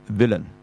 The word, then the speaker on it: villain: 呢個字咧就完全即係第二個 I 啊，其實基本上係唔發音嘅，就叫做 villain 啊，而家短音嘅嚇 villain。